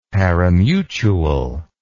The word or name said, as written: parimutuel